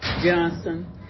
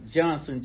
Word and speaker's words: Johnson: Geneva Johnson Eberhardt. – And can you spell that just for me real fast? – G-E-N-E-Z-A, Geneva Johnson, J-O-H-N-S-O-N, Eberhardt, E-B-E-R-H-A-R-T.